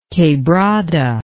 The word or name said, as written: quebrada